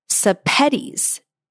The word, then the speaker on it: Sepetys: Her name is actually pronounced Sepetys and rhymes with spaghettis."